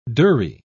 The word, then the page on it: durrie: British English